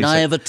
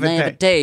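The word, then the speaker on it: naïveté: Yeah, that's how you naivete. – Say it. – Naivete. You got to say it like it's a wine, the naivete.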